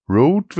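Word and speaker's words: Røde: Zwar bin ich jetzt stolzer Besitzer eines Headsets von Rode, wird es ausgesprochen. R-Ö-D-E, wobei das Ö dieses dänische O mit dem Strich durch ist.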